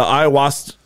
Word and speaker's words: ayahuasca: Yeah, the ayahuasca.